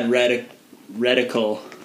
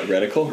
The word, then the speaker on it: reticle: That rectal is. I mean, wait, what? Reticle? – Red. – As the smaller the rectal is, you want a small rectal. – Spell it. – Yeah. – R-E-T-I-C-L-E.